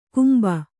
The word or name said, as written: ಕುಂಭ